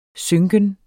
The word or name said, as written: synken